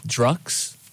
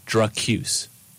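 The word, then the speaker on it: Drukqs: But actually, I learned recently, a little while ago, that it's actually supposed to be pronounced Drucuse. – Drucuse, yeah. – Drucuse, not Drux. – You thought it was Drux? – No, I knew it was Drucuse. – Yeah, you got to emphasize the Q.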